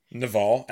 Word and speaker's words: Naval: Naval, N-A-V-A-L. If you just type that in, it'll come up. It's spelled like naval, but it's pronounced like Naval.